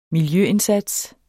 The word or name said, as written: miljøindsats